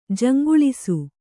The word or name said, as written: ಜಂಗಳಿಸು